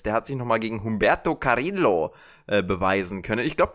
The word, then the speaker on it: Humberto Carrillo: Humberto Carrillo. – Ganz genau, das äh, Doppel-L ist ja ein J.